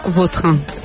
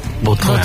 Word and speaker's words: Vautrin: La original francesa, Vautrin. – Vautrin.